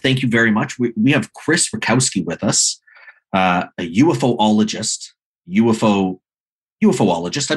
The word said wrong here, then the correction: ufologist: I'm saying that correctly. – No, I'm saying it incorrectly. – Ufologist.